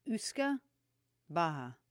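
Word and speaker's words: uisce beatha: So there's a word called, it's U-I-S-C-E-B-E-A-T-H-A. And it's pronounced like Usca Baha, which translates to water of life.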